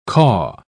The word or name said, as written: caw